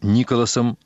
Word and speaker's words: Николас: Доминик, очень энергичная женщина средних лет, вспоминает о своем знаменитом муже, которого называет Николасом, с ударением на первом слоге, с теплотой и юмором.